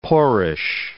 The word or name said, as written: poorish